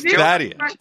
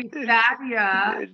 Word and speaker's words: Thaddea: And it's Thadia. – Okay, Thadia.